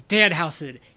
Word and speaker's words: Danhausen: Like, he's like, "It's Danhausen. It's German and evil."